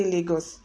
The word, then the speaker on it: Lagos: I don't know Google pronunciation, but you know we are in Nigeria. We say Lagos.